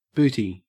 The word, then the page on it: booty: Australian English